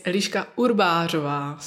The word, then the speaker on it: Eliska Urbarova: Of course, my name is Eliska Urbarova, so I understand that it's a little bit difficult to pronounce.